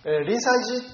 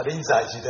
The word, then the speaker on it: 臨済寺: 今静岡市大岩というところに、まあ、私ども子供の頃から、私静岡生まれですから、おじいさんおばあさんに連れられて行ったりしてた時には、臨済寺って聞いてたんですけどね、今住職さんはうちは臨済寺ですと濁って発音してますから、まあ、しょうがないというと怒られますが、まあ、臨済寺で今は発音してますが、まあ、その臨済寺の住職だったところに、まあ、竹千代が通ってです。